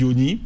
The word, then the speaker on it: Osny: Osni, ça s'écrit O-S-N-Y. – Mais ça se dit Osni.